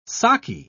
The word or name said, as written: saki